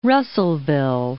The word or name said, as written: Russellville